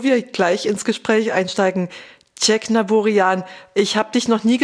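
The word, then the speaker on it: Tjeknavorian: Aber original in der armenischen Sprache ist es erstens das E gibt's nicht, Check gibt's nicht, das ist Cieknavorian. Da ist es tatsächlich am Ende die Betonung, aber Cieknavorian passt.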